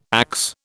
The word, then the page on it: axe: British English